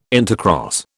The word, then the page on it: intercross: British English